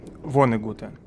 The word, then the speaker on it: Воннегут: Мы решили поговорить про Курта Вонегута, Вонегута, ну, ударение на первый слог, потому что в нашем канале наши дорогие читатели выбрали для нашего книжного клуба про э, прочитать «Колыбель для кошки».